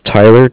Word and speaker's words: Tyler: My name is Tyler, T-Y-L-E-R, and I'm calling with Cardiff. It's spelled C-A-R. D-I-F-F, as in Frank Frank.